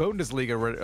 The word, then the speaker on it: Bundesliga: Bundesliga.